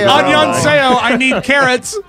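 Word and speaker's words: Annyeonghaseyo (안녕하세요): And then there's a whole bunch of characters that I don't understand that are pronounced Annyeonghaseyo from Seoul. – That's Korean for hello. Annyeonghaseyo.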